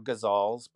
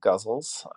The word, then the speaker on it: ghazals: Well, you know, I teach guzzles or ghazals, but I guess they're, you know, you pronounce it guzzles, and to high school students, because high school students are very trepidatious about writing poems, especially if they've never written poems before.